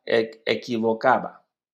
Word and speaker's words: equivocaba: Tú todavía sigues pronunciando la E como I. No, es que... – Y equivocaba. Equivocaba.